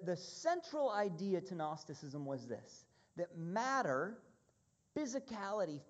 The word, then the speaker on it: Gnosticism: Have you heard of Gnosticism before? It starts with a silent G, Gnosticism.